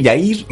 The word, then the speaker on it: Jair: Bueno, eh, ¿cómo se dice Jair? Jair, Jair Bolsonaro. Jair. Jair es como nombre que...